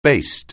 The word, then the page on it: baste: anglais